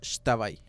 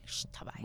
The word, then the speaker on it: Xtabay: Así se pronuncia, como si fuera la X, se pronuncia como si fuera un X. Por eso es Xtabay. – Xtabay.